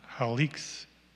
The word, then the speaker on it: Halicz: Halic, I'm not sure if that's the pronunciation, H-A-L-I-C-Z, the city of Halic.